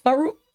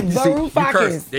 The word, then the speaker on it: Varoufakis: Varoufakis.